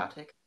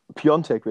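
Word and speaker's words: Piątek: Piontek wird da ausgesprochen, tatsächlich. – Also ich habe, also der Kommentar hat Piatek gesagt. – Ja, ich weiß, die haben auch alle keine Ahnung. Ich habe einen Polen gefragt, ich habe einen Polen gefragt, der meinte, er wird Piontek ausgesprochen und, und in der Fußballwelt ist er auch als Piontek bekannt.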